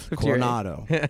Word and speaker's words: Coronado: Did I say that correctly? – Yes, Coronado, Crown City.